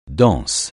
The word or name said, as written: danse